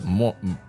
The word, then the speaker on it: Moran: Oh, he pronounces it Moran.